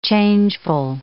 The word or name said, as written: changeful